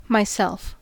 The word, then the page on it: myself: American English